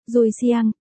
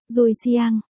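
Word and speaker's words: dùi siang (对象): Trong tiếng Hán, đôi voi, đọc là, đuôi sàng, dùi siang, từ này hoàn toàn trùng âm và trùng cách viết với từ. Đối tượng, có nghĩa là, người yêu, dùi siang, cá chép gấm, bổn thiếu nữ không chơi với cô nữa, nghỉ chưa đây?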